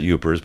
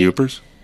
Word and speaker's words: Yoopers: You could pronounce it Upers, but they spell it here Y-O-O-P-E-R-S.